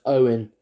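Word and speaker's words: Eoin: And I mean, the poor guy for years has had to put in his fucking biography, it's pronounced Owen. Like, hello, Mr. Ian Cofer. He's like, it's Owen, mate, come on.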